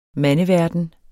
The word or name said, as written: mandeverden